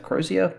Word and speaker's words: Crozier: A Crozier, is that how you pronounce yep. – it? Crozier, paramedic.